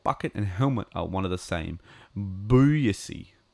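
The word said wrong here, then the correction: buy'ce: or Busha, sorry. Pronounced Busha.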